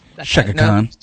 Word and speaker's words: Chaka Khan: Chaka Khan.